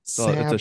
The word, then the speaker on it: Sabu: So Sabu. It's a short A sound, Joe.